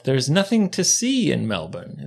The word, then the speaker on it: Melbourne: Yeah, Melbourne. Not Melbourne. – Mm-hmm. – Even though it's spelled Melbourne. – Melbourne. – Bun. Sure.